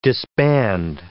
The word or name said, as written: disband